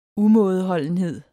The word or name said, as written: umådeholdenhed